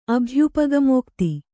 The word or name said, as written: ಅಭ್ಯುಪಗಮೋಕ್ತಿ